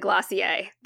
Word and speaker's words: Glossier: Now, I know they pronounce it Glossier, but I refuse to pronounce it like that, because it's not actually a French word.